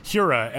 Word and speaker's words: Hiura: I also really like Keston Hiura in the, uh, or Hura, as he said when he called me. I got to start pronouncing his name correctly.